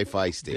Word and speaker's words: feisty: This is a very fisty migrant. I mispronounced feisty. I meant to say feisty.